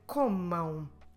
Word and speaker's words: comam: Still stress the second to last syllable, com, but this time we say comam and we close our lips in the end. Very different from falem, the mouth remains open. Now comam, the lips are closed in the end.